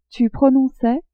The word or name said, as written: tu prononçais